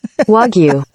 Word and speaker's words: Wagyu: Wagyu. – According to Google. And that is what they are saying in the Arby's commercial now. Wagyu. – Wagyu? – The emphasis is on the wa. W-A.